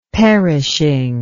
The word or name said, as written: perishing